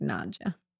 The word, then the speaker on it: Nadja: Nadja. – Nadja? – Okay. It's just like Nadja.